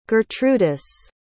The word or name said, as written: gertrudis